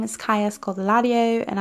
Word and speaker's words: Scodelario: It's Pirates of the Caribbean and it's Kaya Scodelario. It's, it took me forever to figure out how to say her last name, by the way. I would say uh, Scoldario for like the longest time, and then I saw a video of her promoting something.